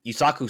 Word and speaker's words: Yusaku: Yusaku sounds correct.